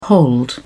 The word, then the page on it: hold up: British English